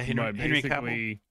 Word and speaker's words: Cavill: Sorry, I'm probably butchering his name. – Yeah, Henry Cavill.